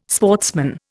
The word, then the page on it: sportsman: Indian